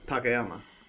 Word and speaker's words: Takayama: And then Takayama. – Takeyama. – Y-A-M-A. – Hisao Takayama. – Anyway, Takayama Hisao.